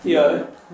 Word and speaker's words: Yeo: Yeo.